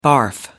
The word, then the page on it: barf: American English